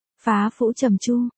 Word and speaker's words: phá phủ trầm chu: Phá phủ trầm chu, Pô Phu Trang Châu, thành ngữ cổ TQ, thực ra thành ngữ này đọc chính xác là phá phủ trầm chu, chữ phủ, dấu ngã chứ không phải dấu hỏi, cũng đọc là phẫu, chiết tự Hán văn là chữ gia, cha, ở trên, chữ kim, vàng, ở dưới.